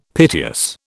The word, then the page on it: piteous: British English